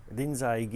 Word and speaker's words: Rinzai: That happens to be the Japanese pronunciation of his name, Rinzai Gigen, who lived sometime in the 8th or 9th century in China.